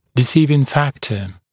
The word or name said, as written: deciding factor